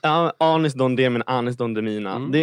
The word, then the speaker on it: Anis Don Demina: Ja, Don Demina, Demina, herregud, alltså för lyssnarnas skull så är Danis Don Demina. – Anis Don Demina.